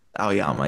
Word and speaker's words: Aoyama: Aoyama, yeah. Not with the emphasis on the O, though.